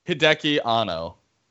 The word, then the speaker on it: Hideki Anno: How you say it? – Hideki Anno.